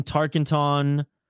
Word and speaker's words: Tarkenton: Fran Tarkington. – Tarkington. – Okay, meat sauce. – Yeah, there was some controversy here in Minnesota, Isha. – Dude, learn how to say the fucking name of a legendary quarterback in Minnesota.